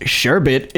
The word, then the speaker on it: sherbet: Sherbet, pronounced sherbet, is the standard American spelling for the frozen mixture made from frozen fruit and additives of either milk, egg, white, or gelatin.